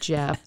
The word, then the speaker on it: Gef: I mean, you can look him up, but it's spelled, he spelled it G-E-F. – Just like Jeff. – It's like Geff. It sounds like Geff, but he pronounced, the mongoose pronounced it Jeff.